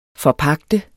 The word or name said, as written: forpagte